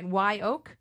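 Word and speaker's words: Wye Oak: All right, let's go to your fifth selection, and this is, I mean, am I saying it right? Why Oak? – Yes.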